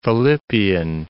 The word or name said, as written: Philippian